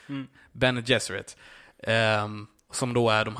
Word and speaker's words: Bene Gesserit: Eh, som kommer handla om det, ursäkta om jag uttalar detta fel nu, Bene Gesserit. – Ja, no, Bene Gesserit.